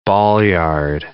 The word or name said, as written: ballyard